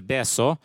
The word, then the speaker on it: Betio: Så den största byn, den tror den heter Betio, heter Beso.